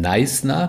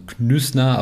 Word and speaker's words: Knysna: Nächste Station ist die, äh, die Stadt ausgesprochen Neisner, bisschen komisch geschrieben, kein y n YN, äh, SNA, also Knüssner, aber Neisner ausgesprochen.